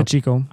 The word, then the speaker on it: Pacheco: Outside of Isaiah Pacheco. Pacheco? I don't know. – Pacheco.